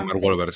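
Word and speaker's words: Mark Wahlberg: creo que el, actor era Mark Welber, ¿no? Se, se pronuncia. – Eh, sí, Mark Welber, sí.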